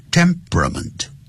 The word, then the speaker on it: temperament: 本来念慢的时候叫做 temperament，可是念快的时候 temperament 变成三个音节 temperament Temperament 这样念，好，就不要念成 temperament，好，记住啊，就像 comfortable 舒适的，念快的时候就变成 comfortable，三个音节 comfortable 这样念的。